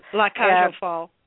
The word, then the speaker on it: La Cage aux Folles: La Cage aux Folles, is that? – Yeah. – Did I? – La Cage aux Folles.